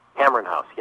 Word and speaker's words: Cameron House: How did you pronounce it? Cameron House? – Cameron House, yeah.